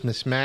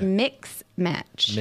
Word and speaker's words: mismatch: Mismatch or mix match? – Mismatch. Because it's not a match.